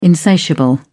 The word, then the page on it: insatiable: British English